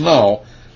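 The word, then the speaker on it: naw (נא): Pray here is the Hebrew word na or naw. It's pronounced naw.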